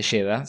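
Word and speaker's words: Jeddah: Y muy interesante, existe la ciudad de Yeda, se escribe distinto, pero la ciudad de Yeda es la puerta de entrada para la Meca, en Arabia Saudita.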